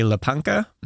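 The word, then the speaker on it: łapanka: And so there's a, he was up in this building where, when a Lapanka began, is that how you pronounce that? – Yes, I would say so. I'm largely guessing because I don't know Polish pronunciation, but I know Czech pronunciation and it's usually quite similar.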